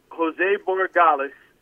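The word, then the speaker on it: Borregales: Is it Jorge Gales? Is that how you say his name? Did I get it right? – Jose Borgales. And okay.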